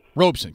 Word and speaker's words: Robeson: I uh, I think it's Robeson. – Robeson, correct.